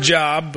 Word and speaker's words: Job: It looks like the word job, but it's pronounced Job.